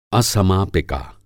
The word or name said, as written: असमापिका